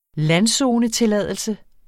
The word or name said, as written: landzonetilladelse